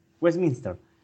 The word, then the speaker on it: Westminster: No te es de ahí, pero derecha, mejor pronunciado. – Claro, Westminster. Bueno, Kuala Lumpur queda en Malasia y es importante porque toda la población sujeto de este experimento fue malaya.